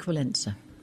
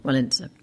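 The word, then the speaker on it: Wałęsa: Lech Walesa. – Really? – Lech Walesa. – I always think of, what is that? That American newscaster in the 80s would always say, Lech Walesa.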